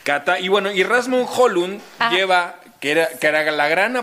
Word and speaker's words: Rasmus Højlund: Digamos que una de las grandes apuestas, justamente hablando de fútbol danés, es Rasmus Højlund.